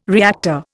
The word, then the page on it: reactor: Indian